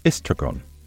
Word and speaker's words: estragon: Tarragon in French is pronounced estragon and spelt E-S-T-R-A-G-O-N.